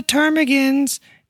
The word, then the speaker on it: ptarmigan: Lots of people don't know the ptarmigans begins with a P. It's a silent P.